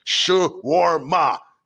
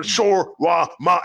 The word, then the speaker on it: shawarma: Because I went to Amir's by Dorval once, and I remember I said it wrong. And the guy's like, I'm not serving you till you say it with me. Shawarma. I'm like, oh, shawarma.